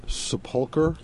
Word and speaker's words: sepulcher: The loss of right of sepulcher. Is that how you pronounce it? – Yes, yes. That's you want to explain that? – A word that refers to entombment.